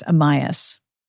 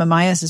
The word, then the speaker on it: Amyas: When he was a foundling child, all right, the first name is spelled A-M-Y-A-S, and I'm guessing that is Amias. So that's how I'm going with it.